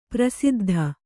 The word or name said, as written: ಪ್ರಸಿದ್ಧಿ